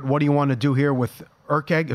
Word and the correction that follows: Erceg: if I'm pronouncing it right, Erkeg, Erkeg. – Erceg fighting Schnell.